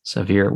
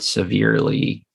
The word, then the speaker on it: Sahvir: S-A-H-V-I-R. Sahvir Wheeler. Okay. So not severely. – But still, it's pronounced Sahvir.